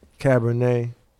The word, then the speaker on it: Cabernet: Can somebody pass these joints to some more of that uh, Cabernet. Did I say that right? Mm-hmm. Cabernet.